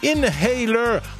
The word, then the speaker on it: Inhaler: Allez, on écoute Inhaler, My Honest Face, parce qu'on ne prononce pas le H de Inhaler.